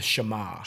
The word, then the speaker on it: Shema: What was that word? Shema? Did I say that right? – The Shema.